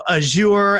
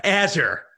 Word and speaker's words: Azure: Wow, you really butchered Azure. Azure. Sorry about that, guys. Boom. It's not Azure. Azure.